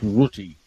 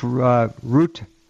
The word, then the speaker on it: Rutte: Chief Rutte, R-U-T-T-E. There we go. NATO Chief Rutte. Again, it's pronounced Rutte. – Rutte. Okay. Well, yeah.